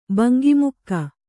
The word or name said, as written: ಬಂಗಿಮುಕ್ಕ